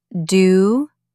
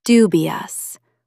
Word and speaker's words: dubious: Stress the first syllable. Dubious.